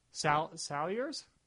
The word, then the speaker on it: Salyers: Or S-A-L-Y-E-R-S. Salyers? Pronunciation is not my strongest suit. I realize that. And you feel like that's kind of ironic in an audio-based format. – I would call that Salyers.